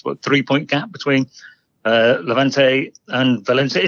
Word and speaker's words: Levante: And they lost this weekend to Levante.